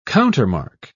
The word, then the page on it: countermark: British English